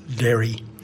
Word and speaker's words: Daoiri: So Derry Farrell, D-A-O-I-R-I, spells his first name if you're going to look him up. D-A-O-I-R-I, pronounced Derry. Farrell, F-A-R-R-E-L-L.